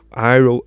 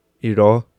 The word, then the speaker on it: Iroh: ang é Ang, então eles conseguem, é, sério, Iroh, Uncle Iroh, Tio Iroh, eles fazem a, pronúncia de iró. É tipo, é tudo errado.